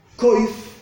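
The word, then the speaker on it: Koif (קוף): En hebreo, Koif, que se escribe exactamente igual que Kuf, Kuf, Vav, Fei, quiere decir mono.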